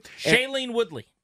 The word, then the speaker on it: Shailene Woodley: Shailene Woodley.